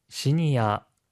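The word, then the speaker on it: シニア: 시니아, 주니아와 같이 일본어의 외래어에서 어 발음은 보통 아로 발음되는 경우가 많습니다.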